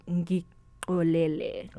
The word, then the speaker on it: Ngikolele: So I'm guessing Shilo Lele. – Oh, wow. So I don't even think I pronounced it right myself. I know that it's Xhosa. So the song's called Ngikolele.